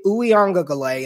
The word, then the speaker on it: Uiagalelei: I would be – if DJ Uiagalelei, and that is how you pronounce his last name,